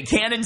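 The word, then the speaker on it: Kannon: My name's Cannon with a K. Cannon spelled with a K in French, avec un K.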